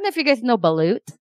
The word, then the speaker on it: Balut: Balut. Oh, there you go. Oh, hey, you can pronounce it right.